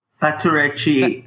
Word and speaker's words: Faturechi: Faturechi.